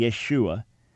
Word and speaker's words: Yeshua: Jews for Jesus popularized this by having it as written as Y apostrophe S-H-U-A, so people would say Yeshua. The proper pronunciation would be Yeshua, but they had it that people would say Yeshua, the reason being that they thought people would mispronounce it even more if it was spelled correctly.